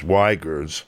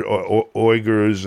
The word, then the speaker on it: Uyghurs: The religious card, And the way they pronounce it at Wikipedia is Uyghurs, but I've heard Uyghurs and others, and I don't know in the native language, but it's a Turkic ethnic group, just for people who don't know.